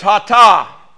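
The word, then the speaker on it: hatah (חטא): It's hatah. You'll see it spelled with a C-H, you know.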